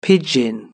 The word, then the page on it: pigeon: British English